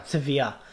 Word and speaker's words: Sevilla: Sevilla.